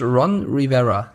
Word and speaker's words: Ron Rivera: Ähm, und zwar hat Coach Ron Rivera. Ja, ich habe den Namen richtig ausgesprochen.